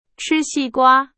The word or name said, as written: 瓜